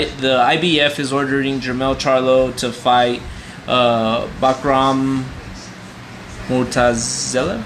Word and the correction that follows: Bakhram Murtazaliev: How do you pronounce that, Rigo? You're better at that. – Uh, yeah, Bakhram Murtazaliev.